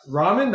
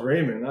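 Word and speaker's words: Ramin Djawadi: Ramen, that's how you say it? – That's how I said it. – I thought it was Raymond.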